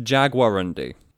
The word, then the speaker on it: Jaguarundi: So, it's pronounced Jaguarundi.